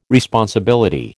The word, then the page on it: responsibility: American English